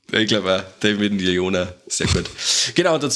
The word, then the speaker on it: Leone: auch unter der Regie von Damien Leone. Oder Leone, wie spricht man den aus? – Leona, genau.